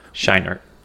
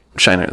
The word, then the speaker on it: Scheinert: I don't even know how it's spelled, so spell it. – S-C-H-E-I-N-E-R-T. – Schenert. – Schenert, that sounds right, yeah.